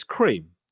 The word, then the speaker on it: Krispy Kreme: Was Krispy Kreme originally meant to be pronounced Krem? I said. – Yeah. – Hey, hope you're well. We've always been pronounced as cream.